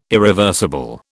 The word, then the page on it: irreversible: British English